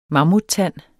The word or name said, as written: mammuttand